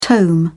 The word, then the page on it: tome: British English